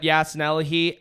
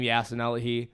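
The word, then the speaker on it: Yasin Elahee: And for my socials, Instagram at Yasinelahi, Apple Music, Tidal and stuff, Spotify. – Tidal. – Wow. Listen to my music. with the name Yasinelahi. – Can you spell it? – Y-A-S-I-N space E-L-A-H-E-E.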